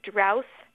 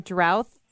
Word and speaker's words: drouth: So if you're writing it as D-R-O-U-G-H-T, you should properly be saying drought. But if you write it as D-R-O-U-T-H, the pronunciation is drouth.